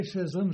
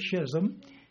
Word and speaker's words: schism: Some people say schism, some pronounce the H, schism. It's correct both ways.